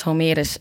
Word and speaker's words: Homerus: En pas later gingen grote Griekse toneelschrijvers, zoals Homerus, zeg ik het goed? – Ja, volgens mij wel. Homerus.